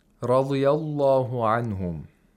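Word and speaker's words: Radiyallahu anhum (رضي الله عنهم): Radiyallahu anhum. Pay attention to how ra is pronounced with a fatha. It's pronounced with tafkhim. – Thickly. – With thickness. Rabbihim.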